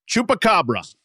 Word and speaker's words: Chupacabra: Chupacabra.